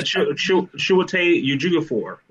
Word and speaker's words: Chiwetel Ejiofor: Some Marvel news came out with Chitwell Adeofor. I hope I said his name right. He plays Baron Mordo in Doctor Strange. – Yeah. – Oh yeah, Chiwetel Ejiofor.